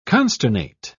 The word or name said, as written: consternate